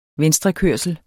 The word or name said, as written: venstrekørsel